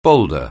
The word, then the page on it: boulder: anglais